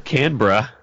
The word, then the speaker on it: Canberra: All right, it was Canberra. – That's it. Like a true Aussie. – Bruh. Australia. Yeah, man. – Nice. – Tasman Stacey signed up, so he is the location lead in Canberra.